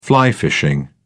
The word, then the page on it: fly fishing: British English